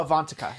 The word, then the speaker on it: Ivanka: Like Ivanka. Is that how you say her name? Ivanka? – Oh, Ivanka. Yeah. Ivanka. Mean Girls.